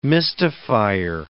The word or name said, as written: mystifier